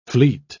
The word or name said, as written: fleet